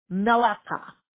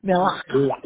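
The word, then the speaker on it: melachah: It is spelled M as in Mary, E-L-A-C-H-A-H. I'll spell it again. M as in Mary, E-L-A-C-H-A-H. Malakha. That's when you got to put your tongue in the back of your throat and say, Melacha. Yeah. M-E-L-A, and I'm sure I'm not quite pronouncing it precisely, but that's close. M-E-L-A-C-H-A-A.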